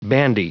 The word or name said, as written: bandy